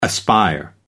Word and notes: aspire: American English

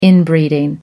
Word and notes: inbreeding: American English

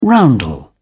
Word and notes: roundel: British English

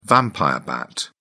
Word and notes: vampire bat: British English

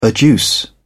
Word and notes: adduce: British English

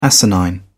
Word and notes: asinine: British English

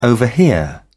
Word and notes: overhear: British English

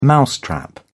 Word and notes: mousetrap: British English

